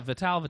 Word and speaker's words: Vitale: Is it Vitale? – Vitale. – Okay. So just think about what you used to put in your hair when you were in high school. Vitalis. Just add Vitale.